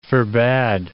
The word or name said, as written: forbade